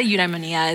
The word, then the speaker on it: eudaimonia: But it's eudaimonia. – Yeah. There you go. Yeah. I've come across so many ways to pronounce this, but I say eudaimonia as well.